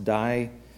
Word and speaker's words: Day: And then the Dai, D-A-Y, but it's pronounced Dai, Bible is just about to be finished as well.